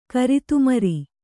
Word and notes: ಕರಿತುಮರಿ: kannada

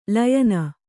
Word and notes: ಲಯನ: Kannada

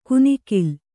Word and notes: ಕುನಿಕಿಲ್: kunikil

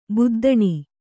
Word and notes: ಬದ್ದನೆ: Kannada

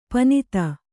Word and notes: ಪನಿತ: panita

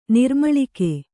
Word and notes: ನೀರ್ಮುಳುಗು: nīrmuḷugu

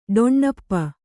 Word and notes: ಡೊಣ್ಣಪ್ಪ: ḍoṇṇappa

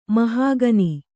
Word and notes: ಮಹಾಘನ: mahāghana

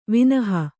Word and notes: ವಿನಹಾ: Kannada

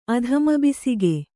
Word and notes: ಅಧಮಬಿಸಿಗೆ: kannada

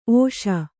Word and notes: ಒಸಿ: Kannada

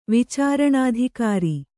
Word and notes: ವಿಚಾರಣಾಧಿಕಾರಿ: kannada

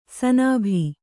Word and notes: ಸನಾಭಿ: sanābhi

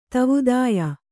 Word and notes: ದೇವಾದಾಯ: dēvādāya

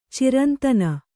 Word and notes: ಚೈರಂತನ: cairantana

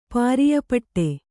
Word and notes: ಪಾರಿಯಪಟ್ಟೆ: kannada

